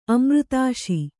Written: ಅಮೃತಾಶಿ

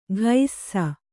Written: ಘೈಸ್ಸ

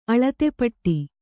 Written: ಅಳತೆಪಟ್ಟಿ

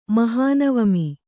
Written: ಮಹನವಮಿ

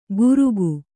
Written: ಘುರುಕು

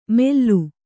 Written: ಮೆಲ್ಲು

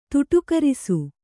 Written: ತುಟುಕರಿಸು

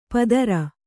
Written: ಪದರ

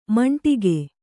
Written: ಮಂಡಿಗೆ

